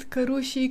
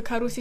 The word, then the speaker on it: Carusi: Carusi, da, am auzit Cărușii, Caruzi, nimeni nu mă crede când zic că se pronunță Carusi și atât mereu e mai mult de atât mereu e, dar nu Cărușii, dar nu Caruzi, dar nu domnișoară, nu-i car...